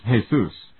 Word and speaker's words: Jesus: The Spanish pronunciation is Jesus, although most Americans pronounce it as if referring to the Christian Savior.